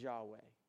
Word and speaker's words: Yahweh: Listen, when God says, I am who I am, he's tying directly back to his name, which is all cap letters, YHWH, which we pronounce as Yahweh, okay?